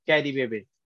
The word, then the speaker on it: Carybebe: Carrie Baby. – Yes, that's correct. So it's pronounced C A R Y, mm -hmm. – and then the word, the other word is B E B E, all together.